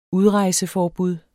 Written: udrejseforbud

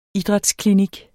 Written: idrætsklinik

